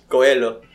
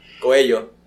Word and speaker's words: Coelho: Kulo. – Kulo. – How do you pronounce it? – Kulo's butt. In Spanish. – In Spanish. – Coelho. Coelho.